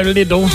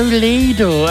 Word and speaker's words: Lidl: It was also the year that Lidl opened its first stores in Britain, depending on how you say it. If you're common like me, you go Lidl. If you're proper, you say, oh, Lidl, oh.